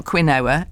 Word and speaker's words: quinoa: How do you pronounce, and it is a food, it's a grain, I think, Q-U-I-N-O-A. – Quinoa. – Well done.